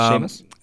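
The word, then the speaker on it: Schamus: I hope I'm pronouncing that right. Shamus. – Yeah, I think it's Shamus.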